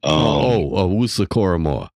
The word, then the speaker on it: Owusu-Koramoah: Owusu-Koramoah.